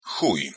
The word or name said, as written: chuj